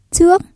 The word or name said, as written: trước